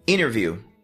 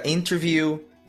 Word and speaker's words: interview: E por último, a gente tem o T, que simplesmente desaparece... quando tá junto com a letra N em palavras e frases como interview, internet, international, interrupt, advantage, percentage, 20, printout, printer, winner, inverno, que é a mesma pronúncia de winner, vencedor, enter. – Lembrando que isso vai acontecer no inglês americano.